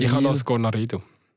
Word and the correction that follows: Cornaredo: Oder wie heisst es? Cornaredo. – Ja, sehr schön. Hey, ich habe es äh, mal richtig gesagt.